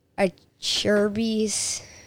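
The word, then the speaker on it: Acerbis: Moose Racing, Alpine Star, Limited Decal, 100%, AVS Levers, OGL Gearbags, Acherby's, and that's it. – Awesome. I'm impressed he pronounced the Cherubis right, because it took me I still wanted to know how to pronounce it, so I finally got it.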